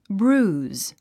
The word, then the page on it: bruise: brúːz; ブルーズ